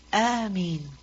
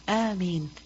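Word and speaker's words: Ameen (آمين): Alif has a mad on it. Meem has no shadda. And after the meem is a ya. Ameen. So it's necessary to pronounce it correctly. Just as we pronounce the words of Surah Al-Fatiha correctly, so Ameen must also be pronounced correctly.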